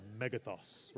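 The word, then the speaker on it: megathos (μέγεθος): Turn to the person next to you and say megathos. You have to say it like that, megathos, right?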